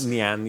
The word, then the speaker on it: Nyan: Like Nyan cat is how I've said it. – But it's like Nyan. You got it.